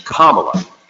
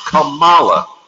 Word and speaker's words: Kamala: It's Kamala. Accent on the first syllable, as in first class woman who gonna be the first black woman president someday. Not Kamala, you second class president who can't please your own wife, Melania, for more than a second.